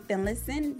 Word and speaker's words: Finlayson: I am on Facebook up under Bri Finlayson.